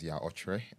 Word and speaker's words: Okyere: Yeah, my name is Okyere. I like to pronounce it the proper way.